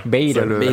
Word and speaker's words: Bader: En Bader, vous le prononcez comme la bande à Bader. Bader.